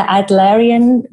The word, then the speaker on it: Adlerian: Do you do the Adlerian methodology or protocol? Am I correct in my pronunciation? – Yes, well done.